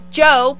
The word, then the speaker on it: Djo: Tuesday, July 28th, Tame Impala with Joe playing at TD Garden in Boston. – Ooh. – It's spelled D-J-O.